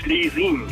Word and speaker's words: Schlesien: In der Gemeinde Vielang, da gibt es einen Ort, der heißt Schlesien. Genau wie gesprochen, auch geschrieben, wird aber ausgesprochen Schlesien.